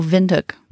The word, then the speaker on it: Windhoek: Oh, Windhoek. – Windhoek.